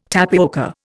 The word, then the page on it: tapioca: Indian